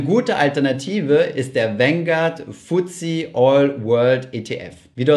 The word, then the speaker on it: FTSE: FTSE oder kurz FTSE ausgesprochen ist ein anderer Indexanbieter, der mit MSCI in Konkurrenz steht.